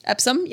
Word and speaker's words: Epsom: Did I say that right? Epsom? – Epsom, yep.